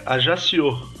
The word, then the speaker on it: Ajaccio: Como é que pronuncia esse negócio? Eu, ó, até onde eu sei é Ajaccio.